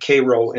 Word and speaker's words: Cairo: They pronounce it Cairo in that area.